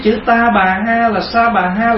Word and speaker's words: sa bà: Nhưng mà chữ ta bà là mình phát âm đúng ra cái chữ mà tiếng Phạn đó gọi là sa ha. Ta bà là sa ha. Thành thử nếu mình nói đúng là mình đọc là sa bà. Bây giờ mình đọc là ta bà, đọc nó chạy rồi.